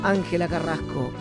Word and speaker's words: Ángela Carrasco: Y hasta ahora nos pidieron la música romántica de Ángela Terrasco. Románticamente tuya en la noche, Ángela Carrasco, querer.